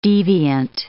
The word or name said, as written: deviant